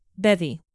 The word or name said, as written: bevy